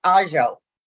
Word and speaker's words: ajo: And in Ladino was pronounced ajo. – Ah. – Which is pronounced. – Oh. – Say, yeah, ija. Instead of iha, like daughter. So yeah.